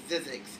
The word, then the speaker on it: Zzyzx: And then I found out later it's pronounced Zzyzx, but it was it was named that as a practical joke because there's no fucking way.